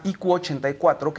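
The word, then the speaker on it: 1Q84: Y la tercera novela, que es la más vendida, que se llama IQ84, que Q eh, es, en, en japonés se pronuncia casi igual que 9, entonces es una variación de 1984.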